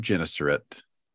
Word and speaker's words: Gennesaret: The Lake of Gennesaret.